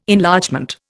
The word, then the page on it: Enlargement: Indian